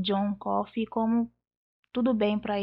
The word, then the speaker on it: Coffey: Que ele fala John Coffey, é igual café, mas não se escreve igual não.